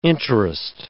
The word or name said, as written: interest